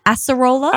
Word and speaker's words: acerola: Acerola? – Acerola.